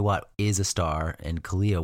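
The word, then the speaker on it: Kealia: Well, people used to be mad at the way Kalia Watt, is that how you say it? Kalia? – Yeah. Pretty sure.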